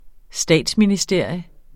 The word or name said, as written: statsministerium